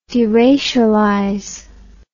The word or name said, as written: deracialization